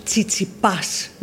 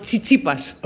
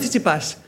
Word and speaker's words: Tsitsipas: It's Stefanos Tsitsipas, not Tsitsipas. – Okay. Stefanos. – Yes. – Tsitsipas. – Yes, that's it. Okay. Stefanos Tsitsipas.